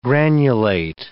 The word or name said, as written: granulate